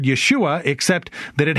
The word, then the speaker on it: Yeshua: First of all, he is Yeshua, or if you pronounce it in its anglicized form, Jesus.